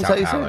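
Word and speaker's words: São Paulo: Last year, forgive me if I butcher the city, is it Sao Paulo? Sao Paulo, Brazil? – Mm-hmm. Sao Paulo, yeah. – Sao Paulo? Not to be confused with Sao Paulo Antonio from ESPN. But last year, Sao Paulo registered 4.2 murders per 100,000 people.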